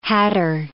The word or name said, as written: hatter